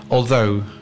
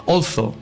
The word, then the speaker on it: although: And the other one would be although, okay? Although is the correct pronunciation. Although some people tend to say also, also.